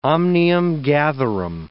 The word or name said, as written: omnium-gatherum